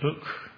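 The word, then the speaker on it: buk: It's actually, in the Hebrew, it's B-U-K, but we can pronounce it book.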